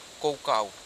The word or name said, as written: kokau